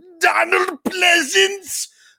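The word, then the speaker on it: Pleasence: that I wanted more Donald Pleasance, but Sorry, if you're going to say his name, that's how you got to say his name.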